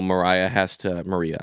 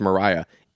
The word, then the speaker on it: Maria: I've been calling her Maria. Then I, all of a sudden, I started hearing all this nonsense that is pronounced Mariah. It's not.